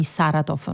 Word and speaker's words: Saratov: Allora io partirei con te dalle ultime notizie con gli attacchi nella notte su Kiev, ma anche sulla città russa di Saratov. Non so se la pronuncia è corretta. – Sì, esattamente, proprio così,